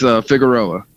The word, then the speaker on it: Figueroa: It was close. It's Figueroa.